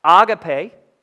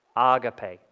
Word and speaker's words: agape: All right, and that's agape. I listened to it like five times. That's how you say it. Agape, not agape. Agape.